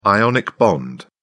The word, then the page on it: ionic bond: British English